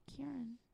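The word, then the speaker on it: Ciarán: Because you said Ciaran just now. – Did I? – Mm-hmm. – I meant Kieran.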